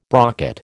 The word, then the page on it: brocket: American English